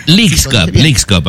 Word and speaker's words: Leagues Cup: Lo dije bien, ¿verdad? – Leagues Cup, ajá.